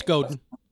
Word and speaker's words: Godin: And I don't know if it's Godin or Godin, pronouncing his name. It's Godin. – Is it? – Yeah, it's Godin, yeah.